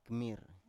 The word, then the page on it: kmir: Biak